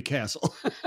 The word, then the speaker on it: Kascel: You know, if you look at it phonetically, you're going to, my old boss as a joke used to call me Mr. Kascel because he was a Serbian who spent a lot of time in Italy. And he said, phonetically, your name to me is Kascel. – Yeah. – I said, I don't care. It's pronounced Castle.